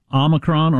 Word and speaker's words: Omicron: And it says here that Omicron is pronounced, well, it's got two pronunciations, so I guess that means you can do it either way. Omicron or Omicron. So you can do either one.